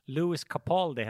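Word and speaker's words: Lewis Capaldi: men Lu Luis Capaldi? Capaldi? – Du lyckades uttala det superfel. Louis Capaldi heter han. Capaldi, ja exakt.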